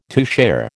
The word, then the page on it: toucher: American English